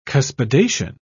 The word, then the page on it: cuspidation: British English